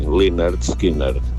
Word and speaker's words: Lynyrd Skynyrd: Pues yo te puedo asegurar que es así, Lynyrd Skynyrd, porque la Y sí. En inglés se lee I.